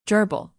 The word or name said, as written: gerbil